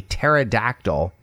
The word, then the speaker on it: pterodactyl: So this joke is, why is it impossible to hear a pterodactyl, that's a dinosaur, going to the bathroom? – Why is that? – Because the P is silent. Pterodactyl, P-T-E-R-O-D-A-C-T-Y-L.